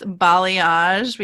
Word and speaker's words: balayage: But we know it's balayage.